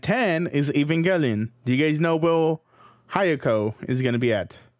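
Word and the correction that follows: Hyouka: Or is that, that's not even how you pronounce it, is it? Hayoko? – Nope. Your cheat sheet's doing wonders. – Hyoka? – Yeah. Hyoka. Hyoka is going to be at number 11, behind Evangelion.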